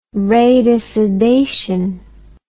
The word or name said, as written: radicidation